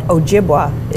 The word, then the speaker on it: Ojibwa: So I also learned Chippewa, home of my, I just love that university, but Ojibwa is the Native American pronunciation of Chippewa.